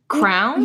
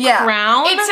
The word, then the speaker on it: crayon: So on the box, are they called crowns? – No, they're spelled like crayon. – Oh my God, but you pronounce it crown?